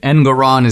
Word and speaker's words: Engoron: The judge, an extreme leftist, he, what is it, Engoron, is that the guy's name? It rhymes with moron.